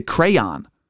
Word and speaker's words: crayon: For example, so like in Rochester or, you know, Buffalo, Western New York, wherever you are listening, we pronounce the word as crayon, right? But a lot of people I know who live in the Massachusetts area, they pronounce it crayon, which actually makes more sense if you consider how it's spelled. I mean, crayon versus crayon.